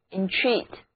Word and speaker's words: entreat: 如果把 re 前缀换成 en 前缀， en 表示使什么什么怎么样， treat 表示对待，那使别人如何对待自己往往都是用一种恳求的语气，所以 entreat 意思就是恳求或者祈求，第二个音节是重读音节 entreat。做错了事情就要请求别人的原谅，所以我说 I entreated your pardon, I entreated your pardon, 我请求你原谅。说这句话的时候一定要态度诚恳才可以哦。那向某人求情就可以说 entreat somebody to show mercy.